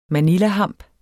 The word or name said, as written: manilahamp